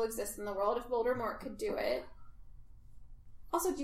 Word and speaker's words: Voldemort: Supposed to be Voldemort. – T is silent. French.